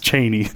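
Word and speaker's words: Chani: In the book, or the audiobook, it's pronounced Chaney, which is really funny.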